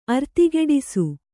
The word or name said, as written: ಅರ್ತಿಗೆಡಿಸು